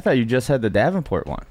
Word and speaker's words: Davenport: Um, Davenport. I say that right? – Yeah, Davenport.